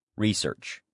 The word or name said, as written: research